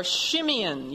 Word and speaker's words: Simeon: Now, the second of Jacob's sons was named Simeon. Or Shimeon, you would pronounce it, which means hearing.